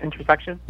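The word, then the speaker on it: Interxion: Another company that I like a lot is a data warehousing, and I'm sorry, a data farm company based in Europe called Interaction. I don't know how to pronounce it. Intersection.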